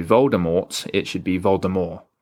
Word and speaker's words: Voldemort: Remember that Voldemort, the T is silent. So it's actually pronounced Voldemort.